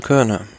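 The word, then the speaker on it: Kuurne: Körne, Brüssel, Körne.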